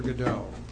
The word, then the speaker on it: Godot: First of all, there's a famous play, Waiting for Godot, although it's spelled G-O-D-O-T, by Samuel Beckett.